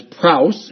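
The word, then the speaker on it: praus (πραΰς): One authority on the Greek text says that this Greek word is found in, that is praus, is the way it's pronounced, is used in one special sense.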